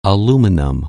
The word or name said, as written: aluminum